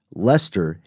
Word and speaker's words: Leicester: But it was spelled like the name of the town in England, Leicester, which looks like it should be pronounced Leicester. That's L-E-I-C-E-S-T-E-R.